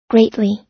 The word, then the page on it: greatly: American English